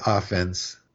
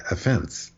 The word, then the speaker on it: offense: In sports, you play offense or defense, right? But if you commit an offense, it's the second syllable. It's, you don't say that he was given a ticket and that was his third offense. You say third offense.